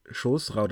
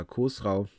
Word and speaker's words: Chosrau: Naja, also das war, worauf du jetzt anspielst, das war Chosrau der Zweite oder Khosrau, je nachdem, wie man es aussprechen mag. Auf jeden Fall mit CH am Anfang.